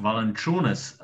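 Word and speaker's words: Valančiūnas: Valančunas, a i nije Jasikevičus, nego Jasikevičus. – Jasikevičus. – Jasikevičus i Valančunas, nemaju taj i koji smo mi navikli.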